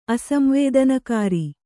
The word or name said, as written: ಅಸಂವೇದನಕಾರಿ